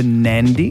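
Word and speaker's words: Nadi: Flights out of the Gold Coast to Nandi.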